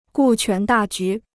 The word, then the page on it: 顾全大局: gù quán dà jú